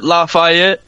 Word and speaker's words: Lafayette: Yeah, we pronounce it Lafayette.